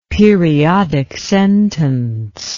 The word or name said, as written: periodic sentence